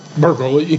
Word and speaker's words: Berkeley: Berkeley. – Berkeley. – that's apparently how it's pronounced.